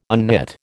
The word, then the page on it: unknit: American English